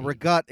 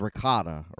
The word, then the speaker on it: ricotta: Now, rigotte is, in English, is ricotta, right? – Yes. Mm-hmm. Yeah, that's true. That's how it's spelled, but not how it's pronounced.